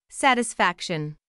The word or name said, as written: satisfaction